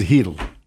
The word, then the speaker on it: Chytil: Philip Chytil. – That's the phonetic spelling if you look at it. His name is Hedl. – Hedl? – Hedl. That's how it's pronounced.